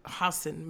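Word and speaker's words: Hasan: Hassan, sorry, that's how you pronounce his name, Hassan Minaj.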